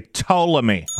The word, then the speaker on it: Ptolemy: I'm just going to pretend that the P is silent and say Ptolemy.